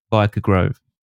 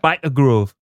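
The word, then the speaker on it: Byker Grove: Biker Grove. – No, you got to say it right. – Biker Grove. – There we go.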